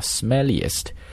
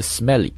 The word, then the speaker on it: smell: 在这里，我们可以看到一个词叫 smelliest，它的原型是形容词 smelly，来自于动词那个 smell 这个词。那么这个词呢，在读音当中，我得多给大家提个醒，千万要把它跟这个微笑这个单词要给它区分开来。